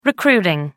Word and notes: recruiting: American English

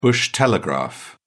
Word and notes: bush telegraph: British English

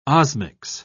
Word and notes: osmics: British English

